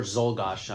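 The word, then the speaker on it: Czolgosz: Zolgosh. – Yes. – Or Zolgosh. I've heard it pronounced both ways, He's quickly tried, executed, it's over.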